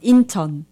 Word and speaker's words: Incheon: And they are from, I don't know if I'm pronouncing this right, Incheon? Is that how you pronounce this South Korean city? – Oh, yes. Incheon.